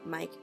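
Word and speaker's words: Meik: Em, el autor se llama Mike Viking, se escribe M-E-I-K y Viking es W-I-K-I-N-G.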